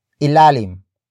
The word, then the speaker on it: ilalim: But first we have ilalim.